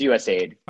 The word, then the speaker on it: USAID: Is it USAID or USAID? I never know which way to pronounce it. – People say USAID. Like, you know, okay. DFID is in the UK, it's USAID.